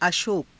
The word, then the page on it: ashoka: Hindi